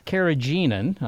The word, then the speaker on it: carrageenan: Well, carrageenan, I'm not sure I pronounced that correctly.